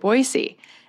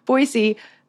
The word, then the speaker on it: Boise: you better pronounce it Boise. And I immediately did that. And I've been pronouncing it Boise ever since, even though for the first like 25 years of my life, I always called it Boise.